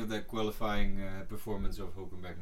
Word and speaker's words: Hülkenberg: And we cannot forget Hülkenberg. – Yes. – I look at Nancy because she's explained to me the proper pronunciation, and I hope I'm saying his last name. – Good. – Okay, good.